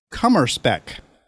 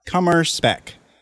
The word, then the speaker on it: Kummerspeck: Kummerspeck. Anyone want to take a guess what that might possibly, unless you already know? Kummerspeck? Kummerspeck.